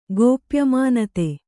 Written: ಗೋಪ್ಯಮಾನತೆ

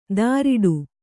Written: ಧಾರಿಡು

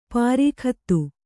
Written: ಪರಿಗತ

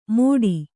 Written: ಮೋಟಿ

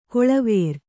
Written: ಕೊಳವೇರು

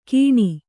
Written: ಕೀಣಿ

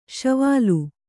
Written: ಶೈವಲಿ

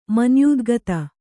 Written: ಮನ್ಯೂದ್ಗತ

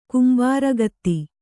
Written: ಕಂಬಾರಗಿತ್ತಿ

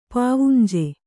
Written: ಪಾವುಂಜೆ

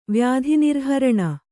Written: ವ್ಯಾಧಿನಿರ್ಹರಣ

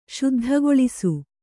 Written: ಶುದ್ಧಿಗೊಳಿಸು

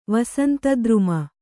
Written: ವಸಂತದ್ರುಮ

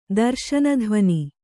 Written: ದರ್ಶನಧ್ವನಿ

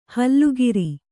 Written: ಹುಲ್ಲೆಕರು

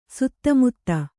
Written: ಸಿತಮತಿ

